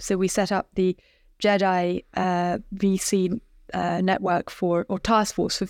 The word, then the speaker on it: GEDI: By the way, the fund is called GEDI, Gender Equity Diversity Investments, GEDI, a soft G.